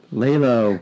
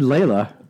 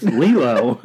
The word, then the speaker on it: Lilo: Lilo. – Wow. Jason Lilo. Got to correct his pronunciation. – Oh, shit. The world is a turning. – I said Layla.